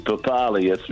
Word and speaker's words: Papali'i: Like Papali'i. It used to be Papali. It's now Papali'i.